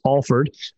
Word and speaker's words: Alford: It's A-L-F-O-R-D for people at home, but it's pronounced Alford.